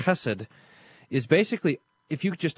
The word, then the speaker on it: Chesed: Chesed. Must be pronounced that way every time.